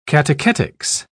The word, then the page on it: catechetic: British English